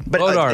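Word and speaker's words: Bois d'Arc: Bodark. – It's spelled... – It's pronounced Bodark, but it's spelled Boys Dark.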